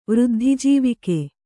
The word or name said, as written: ವೃದ್ಧಿಜೀವಿಕೆ